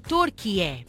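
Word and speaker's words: Türkiye: Em 2022, o país pediu oficialmente às Nações Unidas para passar a ser conhecido internacionalmente por Turquié.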